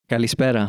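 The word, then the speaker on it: Kallispera (Καλησπέρα): Kallispera? – Kallispera, ja. Det var ett bra uttal faktiskt.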